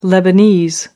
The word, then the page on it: lebanese: American English